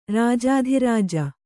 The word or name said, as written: ರಾಜಾಧಿರಾಜ